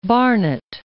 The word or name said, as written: Barnet